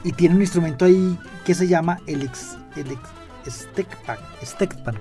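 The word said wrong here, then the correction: steelpan: creo que se pronuncia así, Julio, que es un instrumento tradicional de Trinidad y Tobago. Y sí, tiene su estilo afronáutico. – Un Stilpan se llama.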